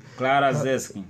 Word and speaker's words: Clara Zetkin: Clara Zetkin.